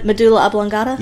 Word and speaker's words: medulla oblongata: How do you say it? Abdullah Oblongata. – Medulla Oblongata? – Yeah, there you go.